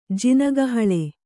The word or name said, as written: ಜಿನಗಹಳೆ